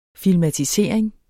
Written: filmatisering

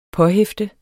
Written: påhæfte

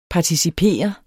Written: participere

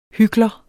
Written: hykler

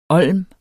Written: olm

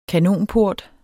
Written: kanonport

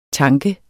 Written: tanke

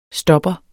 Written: STOPPEREN

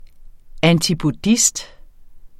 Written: antipodist

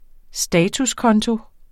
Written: statuskonto

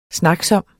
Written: snaksom

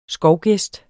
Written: skovgæst

